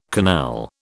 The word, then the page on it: canal: British English